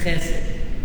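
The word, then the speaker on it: Chesed (חסד): Chesed. You can just say chesed, it's fine. Everybody knows it. Chesed. That's how you say it.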